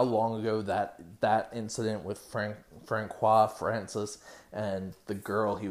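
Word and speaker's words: François: So the one person's name, it's spelled Francois, F-R-A-N-C-O-I-S. They keep on calling him Francis.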